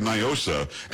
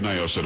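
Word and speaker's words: NIOSA: And here we've always said Nyosa. And for some reason, like the Joaquin Castros of the world think you've got to pronounce it like it's a Spanish word. It doesn't make any sense. I mean, Polo, you say Nyosa, don't you? – Of course.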